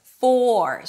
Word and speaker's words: for: So it doesn't sound like fur when it's stressed. For. So for example, when you say something like, who is it for? The word for is stressed, and then I need to pronounce it just like that with a nice long OR as in for. However, when the word for is unstressed, and that's usually the case, then the for is reduced to fur, fur, fur.